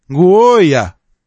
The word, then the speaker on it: Гоя: Гоя. Гоя. Это я попросил, чтобы как произносится название этого Хончи. Гоя.